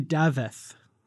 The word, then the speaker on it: Dafydd: I think it's actually Dafydd, I believe. – There's a thith? – Yeah, it's a Welsh name, I believe.